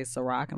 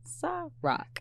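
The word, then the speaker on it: Cîroc: They want to say Ciroc. I'm like, no, it's Ciroc.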